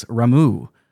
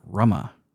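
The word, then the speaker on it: Ramuh: An Esper whose name I learned to pronounce correctly after I started learning Japanese. It is Ramu, not Rama.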